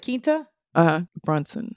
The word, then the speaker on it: Quinta Brunson: So the star of it, Quinta? – Brunson.